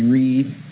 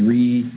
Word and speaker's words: Reed: My name is Ricky Reed. – Step forward, Ricky. – My name is Ricky Reed. – Hey, say your last name. – R-E-E-D.